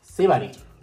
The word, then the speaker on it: Sibari: A ver, pronuncia. ¿Es Sibari o Sibari? – O Sibari. – No, ay, no tenía acento. – Sibari. – Sibari, ¿no? – Sibari, ¿no? Sí. O sea, porque sí. Es de Sibarita, ¿no? – Ajá, como de Sibarita, ¿no?